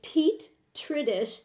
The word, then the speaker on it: Tridish: And so, there's a fellow by the name of Petri Dish. I don't know quite how to pronounce it. That's probably not right. It's Pete Tridish, T-R-I-D-I-S-H.